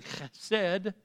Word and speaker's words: chesed: Chesed is how it's pronounced.